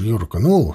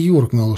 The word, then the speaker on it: юркнул: А уж «юркнул», «шмыгнул», а не «юркнул», «шмыгнул» было просто откровением.